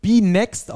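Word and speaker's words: BNXT: dass diese Benelux-Liga, wie ich sie immer gedacht hatte, die heißt gar nicht Benelux-Liga und ist auch keine Benelux-Liga, sondern die wird B-Next ausgesprochen und ist eine Liga, die nur in den Niederlanden und in Belgien gespielt wird.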